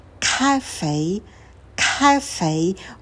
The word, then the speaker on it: cafe: Cafe, cafe, ударение на первый слог.